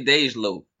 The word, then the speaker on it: Dej Loaf: Is it Dej Loaf or Dej? Like, how do you pronounce her name? – I always say Dej Loaf.